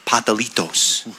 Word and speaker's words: pastelitos: And in very debonair fashion, Frank came packing with pastelitos. Or as they pasta? – Say, I think the more authentic pronunciation is patalitos.